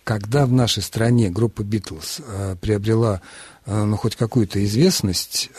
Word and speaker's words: Битлз: По поводу Битлз меня поразило, но у нас есть словарь трудности произношения и ударения Кирилла Сергеевича Горбачевича, да, и он дает это слово просто вот Битлз, и в речи молодежи Битлы, и, видимо, это, безусловно, подразумевается участники группы Битлз.